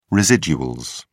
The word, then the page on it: residuals: British English